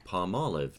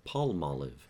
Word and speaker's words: Palmolive: It surprised me because in my mind, palm and olive, you would pronounce that product palmolive, not palmolive.